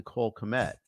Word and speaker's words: Kmet: Earlier, they barely converted a fourth down conversion where they did a direct snap to Cole Kmet.